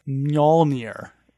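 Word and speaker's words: Mjölnir: Side note, here's the pronunciation of Thor's hammer. It's pronounced Mjolnir. So it's M Yawl is the pronunciation. It's not spelled this way. It's Mjol-Nir. Mjolnir.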